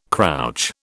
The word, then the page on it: crouch: British English